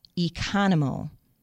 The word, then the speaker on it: Economo: For all you word association people out there, a way to remember my name is that it rhymes with Geronimo. Jill Economo.